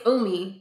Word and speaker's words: Umi: There's a song called Introspection by Yumi. – Umi? – Oh, yes. I see her name wrong. Oh my God, what is it? – Well, it's Umi.